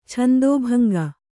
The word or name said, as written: ಛಂದೋಭಂಗ